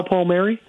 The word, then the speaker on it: Palmieri: Taylor Hall, Kyle Palomari, Drew Stafford. – How did you just say his name? Kyle Palmieri? – Palmieri. – How did you just say that? – Palmieri.